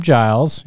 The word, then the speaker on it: Giles: Today our guest is going to be Kim Giles.